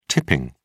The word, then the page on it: tipping: British English